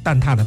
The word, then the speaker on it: 蛋挞: 但如果说你要是写蛋挞的挞提手旁这个达，那就必须得念蛋挞了啊，这是它的一个读音上的一个规则啊。